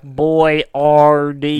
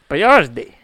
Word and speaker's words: Boiardi: Well, yeah, we had to Americanize it, like Boyardee. – Yeah, we literally spelled it Boyard and E-E, and his name is spelled B-O-I-A-R-D-I. – Boyardee.